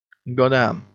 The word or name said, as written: goddamn